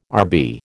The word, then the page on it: Rb: American English